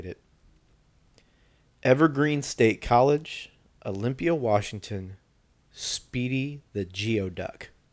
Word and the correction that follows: Geoduck: Also known as, sorry, I mispronounced it. Sorry. Gooey Duck. – Yes. – Is what it's technically called. – It's spelled Geoduck. – It's spelled Geoduck, but it is Gooey Duck.